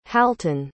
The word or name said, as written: halton